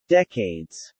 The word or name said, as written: decades